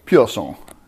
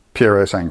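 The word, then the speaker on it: Purosangue: I'm sure it's supposed to be pronounced with an Italian accent, but... – Piero Sangue. – That's what people are going to say, isn't it? – To me, it sounds French. Pure sang. That's... – Yeah. – That's how... Yeah, but it clearly isn't, because it's an Italian car.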